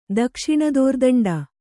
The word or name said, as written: ದಕ್ಷಿಣದೋರ್ದಂಡ